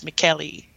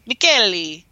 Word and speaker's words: Michele: It looks like Michelle, like in French, you know, it's like Michel. – Yeah. – It's a man's name. But in Italian pronunciation, it's Michele. Michele.